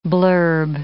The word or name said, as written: blurb